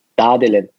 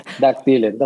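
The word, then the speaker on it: Dağdelen: Das G ist stumm, wie, wie beim Gündogan, dem Fußballspieler. Das G wird fast nicht ausgesprochen. Deswegen Dadelen. Aber ich höre auch auf Dagdelen.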